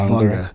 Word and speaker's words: Vanger: The Vanger, V-A-N-G-E-R. – About the Vanger family. So there's a lot. – It might be pronounced Vanga. Vanga.